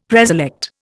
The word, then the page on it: preselect: Indian